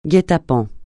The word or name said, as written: guet-apens